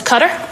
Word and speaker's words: Qatar: What is Qatar? – Qatar, right. And you pronounced it correctly.